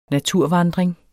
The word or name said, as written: naturvandring